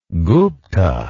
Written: gupta

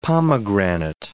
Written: pomegranate

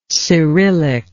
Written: cyrillic

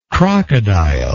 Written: crocodiloid

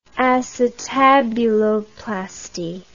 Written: acetabuloplasty

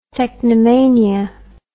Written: technomania

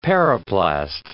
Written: periplast

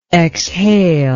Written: exhalable

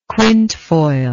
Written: quintefoil

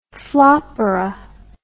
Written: floppola

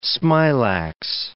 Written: smilax

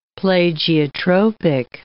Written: plagiotropic

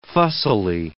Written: fussily

